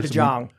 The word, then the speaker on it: DeJong: Today we have a special guest with us, Ben DeYoung, right? – You got it right. – All right, good. – Prefer DeJong. – I've heard my last name pronounced a lot of different ways.